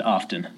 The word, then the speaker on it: often: What happened to the T? Where's the T love? – It got softened out. How did you learn to pronounce often, often? – I think I've always just said often.